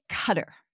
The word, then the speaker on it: Qatar: It's really Qatar. And I know. Just Americans usually call it Qatar.